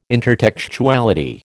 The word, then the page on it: intertextuality: American English